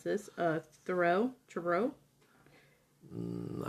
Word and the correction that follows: Trudeau: uh, T R U D E A U. – Trudeau.